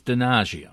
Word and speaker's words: Donaggio: How do you pronounce his name? – Pino Donaggio? – It's Donaggio.